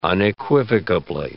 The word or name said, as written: unequivocably